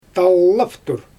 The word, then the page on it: tallaftur: Xyzyl